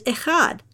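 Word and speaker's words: echad (אֶחָד): That word is echad, and if you say it right, you won't spit over the dog when you say it. That ch sound is in the back of your throat, not in your mouth.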